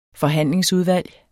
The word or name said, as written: forhandlingsudvalg